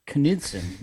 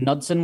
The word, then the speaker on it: Knutson: Knutson. You actually have to pronounce the K? I never would have thought that. But Knudsen was wrong this whole time.